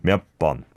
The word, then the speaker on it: 몇 번: I think it's because of the 몇 sound, right? – Yep. You're right. – Pronouncing the 여 there, mm-hmm. but then it needs to go to an 어 sound. 몇 번.